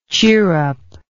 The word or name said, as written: chirrupper